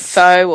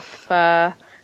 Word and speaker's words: pho: Ooh, you can pronounce it pho or pho.